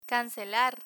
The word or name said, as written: cancelar